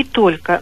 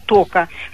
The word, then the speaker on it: только: Я всегда считала, что правильно говорить «сколько» и «только», но часто сейчас в разговорах слышится «сколько», «тока». Объясните, пожалуйста. – Да, вы абсолютно правы. Эти слова все-таки нужно произносить как раз так, как они пишутся.